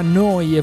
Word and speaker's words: Neu: Neu Radio si scrive Neu, si pronuncia Noi e vuol dire nuova, nuova emittente urbana.